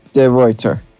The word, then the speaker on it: De Ruyter: I think it's pronounced the Ruyter. – Really quick. De Ruyter.